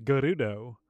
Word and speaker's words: Gerudo: In this, we do run into, it's pronounced Gerudo, Not Gerudo, Gerudo.